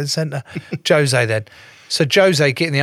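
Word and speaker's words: José: No, it's Portuguese. – What do you mean? – Jose. They pronounce the J's.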